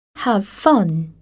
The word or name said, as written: have fun